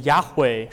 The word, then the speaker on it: Yahweh: 那后来我们今天所发出来的音 Yahweh 实际上是把 Adonai 的元音塞到这个四个辅音当中去，然后形成了 Yahweh 或者耶和华的这个发音。